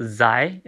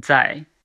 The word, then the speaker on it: Zai: So it's similar to DS in English. So it's not Zai, it's Zai.